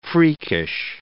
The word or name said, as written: freakish